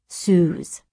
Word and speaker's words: Suze: She was Susie until 9th grade when she dropped the I. It's now pronounced Suze.